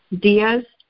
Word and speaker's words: Diaz: Erica Diaz, Cabarra County. – Okay. What was your last name, ma'am? – Diaz, D-I-A-Z. – I'm sorry, spell it again. – D-I-A-Z.